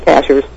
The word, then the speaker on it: Cashiers: As far as the area of Cashiers, maybe I wasn't pretty the accent, the right syllable. But you did it right. It's Cashers.